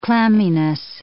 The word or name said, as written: clamminess